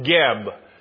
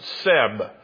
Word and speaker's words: Geb: The Lord tells him, smite the dust of the land, and this is an attack on Geb. Sometimes it's spelled Keb, and sometimes it's pronounced Seb. But who is Geb?